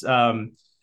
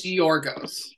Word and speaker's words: Giorgos: I feel pretty confident about the second name, but it's Giorgos Giacomakis. So I think it's Giorgos.